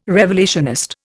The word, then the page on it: revelationist: Indian